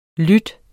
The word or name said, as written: lyd